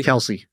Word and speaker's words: Kelce: Kelsey? – Kelsey.